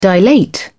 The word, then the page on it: dilate: British English